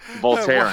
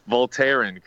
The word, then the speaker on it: Voltaren: You're very welcome, and it just feels so right that you totally butchered the name of that cream also. So it extends to medications. It's not just people. Voltaren cream. – What is not Voltron cream? – Voltaren cream.